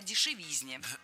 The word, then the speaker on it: дешевизне: Ведь в слове «дешевизна» ударение всегда падает на третий слог. Поэтому должно быть «кстати о дешевизне».